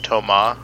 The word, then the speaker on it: Thomas: He doesn't say Thomas. He says Thomas, mm-hmm. – Which is accurate, but it just sort of has that like that like tinge of him just like going out of his way to say the French pronunciation.